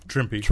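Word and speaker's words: Trimpe: You got Mike Plug and Tom Sutton, Herb Trimp, all Trimpy. Rhymes with Shrimpy.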